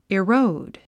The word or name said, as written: erode